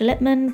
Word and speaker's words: Lipman: Links to all the resources are in the description, and you can find me on socials at Natasha Lipman. That's L-I-P for Peter, M-A-N, because I apparently don't say my name properly.